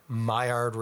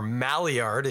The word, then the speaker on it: Maillard: That color, caramelization, Maillard reaction, if you prefer, or Maillard, if you want to pronounce it like a Anglophone, works really well with the burnt sugars in the beer.